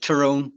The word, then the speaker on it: Tyrone: Yeah, like Tyrone. Yeah. Like Tyr. Instead of Ty, it's Tyr.